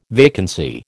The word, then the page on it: vacancy: American English